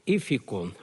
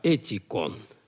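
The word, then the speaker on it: этикон (ἠθικόν): Но в данном случае принято латинское произношение, поэтому читаем уже не «эфикон», а «этикон».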